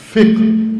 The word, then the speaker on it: فقہ: پاکستانی تلفظ اس کا ہے فقہ، اور صحیح تلفظ ہے اس کا فقہ.